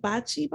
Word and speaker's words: bocce: And I'm not sure what it's pronounced. It's B-O-C-C-E, bocce ball. – I think that's how you say it. Okay. I think it's bocce ball.